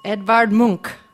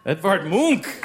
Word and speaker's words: Edvard Munch: Edvard Munch. Edvard Munch. Extra yes. credit for the pronunciation, yes.